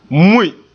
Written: mui

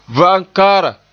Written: va'amkaara

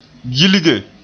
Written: gilge

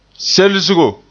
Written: selsgo

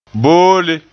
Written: boole

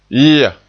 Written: yia

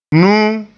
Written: nuu

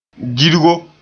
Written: girgo

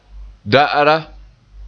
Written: da'ara

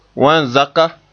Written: wan-zaka

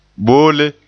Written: bolle

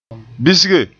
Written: bisge

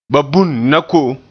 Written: na

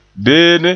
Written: beene

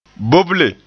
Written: boble

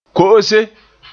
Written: ko'ose

